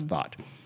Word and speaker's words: Thoth: There's this idea of the, because there was, the, I think it was pronounced as T-H-O-T-H, and I think it's pronounced Thot, one of the ancient Egyptian gods who was a god of magic.